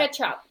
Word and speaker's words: Ketshop: Ketchup. Ketchup is... it's spelled K-E-T-S-H-O-P. So it's kind of like ketchup, the food.